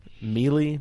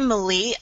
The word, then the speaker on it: melee: Is it melee? Melee? How do you say that word? – Some people say melee. I prefer melee. – Yeah, I've always heard melee in my head, at least.